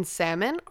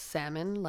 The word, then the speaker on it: salmon: I'm fully convinced that people who pronounce the L in salmon are psychopaths. There's no other reason that you would be pronouncing a silent L in a word.